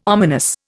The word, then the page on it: ominous: Indian